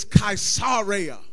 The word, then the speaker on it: Caesarea: And long story short, Paul ends up being transferred to Caesarea or Caesarea. You can pronounce it like you want to. The actual Greek pronunciation is Caesarea.